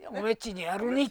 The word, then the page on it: オレ: ore; 雨畑